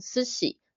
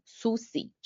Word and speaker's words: 寿司: 比方说像斯喜寿司这个字，蛮、嗯、多的学生会把它发成苏西，就是不知道应成老师有没有发现，就是其实这两个音发音其实是不一样的。